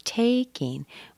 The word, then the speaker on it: taking: There's another content word, taking. We stress the take. The ing is not stressed. And there's no g sound at the end of taking.